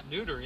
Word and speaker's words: neuter: They have masculine, feminine, and newt. – Did I newt. say it right? – Yeah. Neuter, yeah. Neuter non-binary, whatever there's, you there's call a non-binary Very in inclusive.